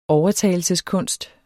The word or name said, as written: overtalelseskunst